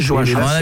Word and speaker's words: Joachim: Souvent, si ça s'écrit Joachim, faut prononcer Joachim. – Ah, moi, J-O-A-C-H-I-M. – C'est Joachim.